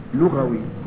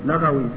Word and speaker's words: لغوي: الإمام المجتهد البحث اللغوي، ولا ينبغي أن نقول اللغوي كما ينطقه أهل زماننا هداهم الله وأصلح حالنا وحالهم فيقولون المجمع اللغوي، هذا خطأ كبير، خطأ فاحش، إنها لغة وليست لغة، فيقال لمن نسب إلى اللغة لغوي ولا يقال لغوي، فاللغوي ينسب إلى اللغو، اللغوي ينسب إلى إيش؟